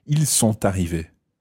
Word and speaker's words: sont arrivés: Okay, in that case, you probably would pronounce the T of sont arrivés. Ils sont arrivés.